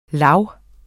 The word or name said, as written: lav